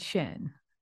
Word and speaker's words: Shen: and Shen.